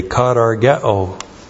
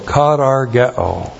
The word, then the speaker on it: katargeo: This glory is a katargeo glory. We have the articular present passive participle of katargeo. Now we learn something about the glory of the Mosaic Law. Katargeo. K-A-T-A-R-G-E long O. Katargeo.